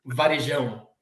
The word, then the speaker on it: Varejão: I think it's a little bit difficult to say, like, Anderson, Varejão is the correct pronunciation in our language.